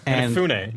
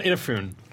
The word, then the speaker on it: Inafune: Inafune, not Inafune, which is my bad.